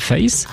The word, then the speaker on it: Faith: Il est le premier morceau extrait de l'album Face, qui, attention faux amis, ne s'écrit pas F-E-2-S-E, ce n'est pas du français, mais F-A-I-T-H, ce qui veut dire foi.